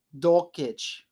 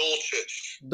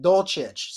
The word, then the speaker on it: Dulcich: Now, my bold prediction has to do with alma mater um, brethren, Greg Dolkic, right? Is that how you say his name? Please confirm. Uh, it's, it's pronounced Dolchich. Dolchich.